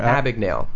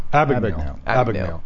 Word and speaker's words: Abagnale: It's pronounce his last name from Abagnale. – Abagnale.